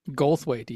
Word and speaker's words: Goldthwait: Goldthwait. – Goldthwait, even. – Goldthwait. I have problems pronouncing that.